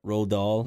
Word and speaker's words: Roald Dahl: Roald Dahl.